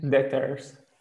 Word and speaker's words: debtors: Debtors. – Debtors. So these are tricky words, which are, you know, very awkward in English. There are others. There are many others with silent letters.